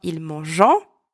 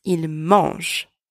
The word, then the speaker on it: ils mangent: So you do not pronounce them. It's not ils mangent, it's ils mangent. Very common mistake.